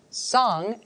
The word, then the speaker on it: Sang: Sang, S-A-N-G, is the first syllable of the word Buddha in Tibetan.